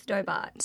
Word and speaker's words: Stobart: Is that how you say it? – Mm-hmm. I think it's Stobart. – Stobart, sorry.